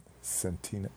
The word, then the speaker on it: Centineo: Centineo.